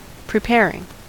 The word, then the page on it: preparing: American English